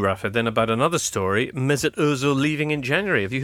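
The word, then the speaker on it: Mesut Özil: Mesut Ozil.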